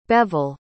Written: Beval

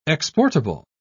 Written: exportable

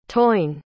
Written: tain

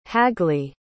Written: hagly

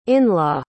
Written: inlaw

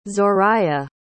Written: zoraya

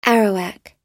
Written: Arawak

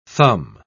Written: thumb

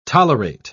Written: tolerate

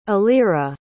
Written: alyra

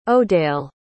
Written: odayle

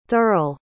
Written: thirl

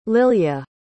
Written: lilya

